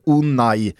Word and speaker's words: Unai: du måste lära dig att han heter Unai Nej, Emery. Det, det är olika det där, beroende på spanska uttal. I Galicien, södra Galicien, där säger man Unai.